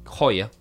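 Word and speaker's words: Hoyer: Para empezar a introducirla, bueno, nació aquí en La Plata, fue discípula de la lamentablemente suicidada, entre comillas, Dore Heyer, una estrictísima profesora de danza alemana, Dore Heyer, Joya, mejor dicho, si lo pronunciara mejor, quien la formó en el Teatro Argentino de La Plata.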